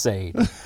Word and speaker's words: Sade: It's actually should be pronounced Sade.